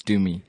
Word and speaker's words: Thumi: I'm known as Thumi.